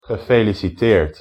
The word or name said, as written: gefeliciteerd